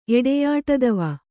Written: ಎಡೆಯಾಟದವ

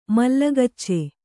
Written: ಮಲ್ಲಗಚ್ಚೆ

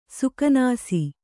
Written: ಸುಕನಾಸಿ

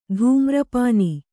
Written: ಧೂಮ್ರಪಾನಿ

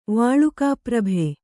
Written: ವಾಲುಕಾಪ್ರಭೆ

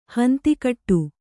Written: ಹಿಂದುಗಡೆ

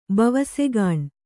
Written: ಬವಸೆಗಾಣ್

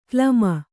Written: ಕ್ಲಮ

